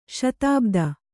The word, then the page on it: ಶತಾಬ್ದಿ: kannada